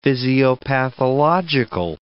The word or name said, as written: physiopathological